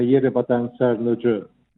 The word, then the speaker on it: Yerebatan Sarnıcı: Più o meno dovrebbe suonare come Yerebatan Sarnıcı, Ok. – perché loro pronunciano le I senza puntino, sono sorde, e la C tendenzialmente si pronuncia G.